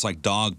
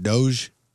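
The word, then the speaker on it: Dogue: D-O-U-G-E. – Oh, D-O-U-G-E. – I'm sorry. D-O-G-U-E. D-O-G-U-E. It's doge pronounced Lee. Like dog, U-E. But it's a snooty French version. – Like, dog. – That's it. He nailed it.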